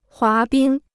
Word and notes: 滑冰: huá bīng

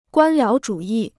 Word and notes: 官僚主义: guān liáo zhǔ yì

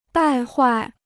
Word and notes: 败坏: bài huài

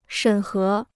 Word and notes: 审核: shěn hé

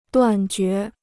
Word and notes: 断绝: duàn jué

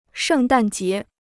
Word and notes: 圣诞节: shèng dàn jié

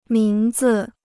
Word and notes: 名字: míng zi

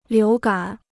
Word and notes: 流感: liú gǎn

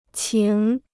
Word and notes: 情: qíng

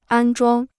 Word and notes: 安装: ān zhuāng

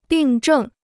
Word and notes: 病症: bìng zhèng

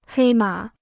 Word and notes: 黑马: hēi mǎ

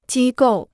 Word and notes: 机构: jī gòu

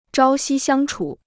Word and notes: 朝夕相处: zhāo xī xiāng chǔ

